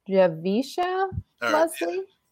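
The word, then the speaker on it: Javicia Leslie: and I believe her name is pronounced Javisha Leslie.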